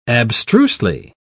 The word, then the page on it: abstrusely: British English